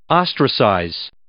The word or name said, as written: ostracize